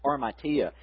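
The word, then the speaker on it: armatia (ἁμαρτία): Begins with an H, but pronounced armatia.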